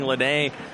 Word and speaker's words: Lada: Lede, again spelled L-A-D-A, but pronounced Lede, out of William Carey University.